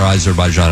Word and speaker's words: Azerbaijan: Now, you told me off the air, Azerbaijan. I'm like, that is how you say it.